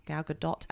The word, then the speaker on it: Gadot: Gal Gadot and Gal Gadot, actually, it's pronounced.